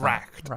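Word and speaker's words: wrack'd: Your shallowest help will hold me up afloat, whilst he upon your soundless deep doth ride, or being raked? How do you pronounce that? – Racked, I would say.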